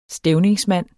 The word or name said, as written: stævningsmand